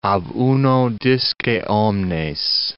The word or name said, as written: ab uno disce omnes